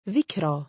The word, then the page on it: dhikraa: female; Arabic